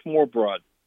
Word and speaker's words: smørrebrød: There's a dish, I'll spell it first. It's SMORRE and then BROD. I think it's pronounced smørrebrød.